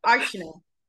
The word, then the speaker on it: Artjanna: Archina. Met de tweede klemtoon, ja, de tweede A spreek je dan net als een E uit.